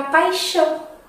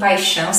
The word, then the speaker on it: paixão: Som do X nós encontramos em xícara, nós encontramos em shampoo. Sim, Shampoo em português se escreve com X, tá? E também na palavra paixão. Sim, paixão se escreve com X também no português. Ok?